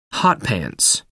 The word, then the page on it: hot pants: American English